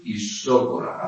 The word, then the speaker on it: isócora: Transformación isócora, acento en la, o sea, tilde en la O. Isócora.